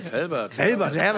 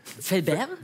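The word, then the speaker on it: Fellbach: Es gibt aber so viele Leute sprechen das falsch aus, weil wir auf der anderen Rheinseite sind, die denken, das ist schon so weit an Frankreich, dass man das. – Fellbert? – Ja, ja. So viele Leute wollen das immer so aussprechen, deswegen du hast das genau richtig ausgesprochen.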